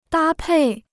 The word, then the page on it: 搭配: dā pèi